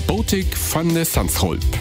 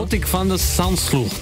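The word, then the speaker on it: Botic van de Zandschulp: In der kommenden Nacht hatte er es zunächst einmal zu tun im Viertelfinale mit dem Niederländer Botik van de Sandschulp. – Eieiei, auch gut ausgesprochen. Britta Kern hat es gestern auch schon richtig gut ausgesprochen bekommen.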